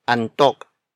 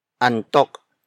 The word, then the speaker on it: antok: Another example, the root word antok. Antok. Antok. Antok is spelled as A-N-T-O-K.